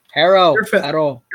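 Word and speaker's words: Herro: But adding Myers Leonard and Tyler Harrow, is that how you gotta say his name, Tyler Harrow? He's like a hero.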